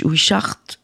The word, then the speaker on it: Wishart: Est-ce que tu peux nous parler de Trevor Wish, Wishart Je le prononce bien Oui, tout à fait bien.